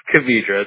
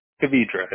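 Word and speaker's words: Kvidera: It's Cavidra. It's a little hard to spell. K, as in Victor, I-D-E-R-A. – How do you pronounce that, Cavidra? – Cavidra. It's Czechoslovakian.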